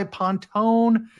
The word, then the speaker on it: Pontone: It looks like Pontoon. I go by Pontone.